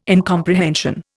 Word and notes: incomprehension: Indian

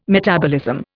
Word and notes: metabolism: Indian

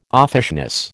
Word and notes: offishness: American English

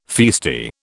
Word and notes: feisty: British English

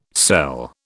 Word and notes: cell: British English

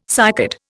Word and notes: cycad: Indian

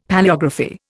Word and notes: Palaeography: Indian

